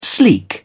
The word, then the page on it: sleek: British English